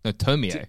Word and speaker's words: Tomie: It's, when you read Japanese, you like you sound out the syllables. – Ah, there you go. Yeah. – So Tomie. – No, Tomie.